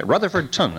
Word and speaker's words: Rutherfordton: But if we go way inland from I-95, a good five-hour drive, about an hour west of Charlotte, we come to Rutherford Town. Okay, Rutherfordton, actually, is the way they pronounce that.